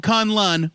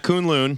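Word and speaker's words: K'un-Lun: In a mystical city called Kun Lun, which is now canon. That's how you pronounce it. Yeah, it's not Kun Lun, like we thought.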